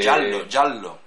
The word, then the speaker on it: giallo: Mario Bava es otro director italiano de lo que se llama el gallo o algo así, que es el cine cutre. – Giallo, amarillo. – Bueno, yo lo llamo gallo porque se escribe gallo.